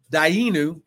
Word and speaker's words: Dayenu: Then they would pronounce that it's a Hebrew word, Dayenu. Everyone say Dayenu. – Dayenu. – And when they go through, you repeat Dayenu when I get done with the prayer. – What does Dayenu mean again? – If it would have been enough.